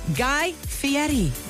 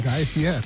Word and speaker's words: Fieri: Peter made sure I said that correctly. Anybody else call him Guy Fieri for the rest, for all of your life? – Gave it to you phonetically. – I know. I didn't know. I learned that today. – Guy Fieri. – Misspell, M-I-S-S-P-E-L-L.